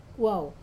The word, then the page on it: kuau: Wamesa